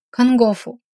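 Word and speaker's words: кангофу (看護婦): Обратите внимание на слог «фу». В японском языке нет слога «ху». Вместо него мы произносим «фу». Например, как в слове «кангофу» – «медсестра» из урока номер один.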